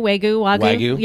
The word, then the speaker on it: Wagyu: Wagyu. – Wagyu.